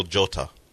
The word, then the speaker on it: Jota: Is that not Diego, how the J is pronounced? – Not for him. It's Diego Jota.